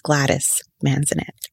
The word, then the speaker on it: Gladys Manzanet: And for those of you that don't understand Spanish, my name is Gladys Manzanet. And that's just a way for them to understand, like, it's a different way of pronunciation.